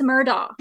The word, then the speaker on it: Murdaugh: And Murdaugh. Like murder. You know what I mean? Murdaugh. Isn't that funny? He has like Murdaugh as his last name.